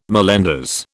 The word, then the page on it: mallenders: British English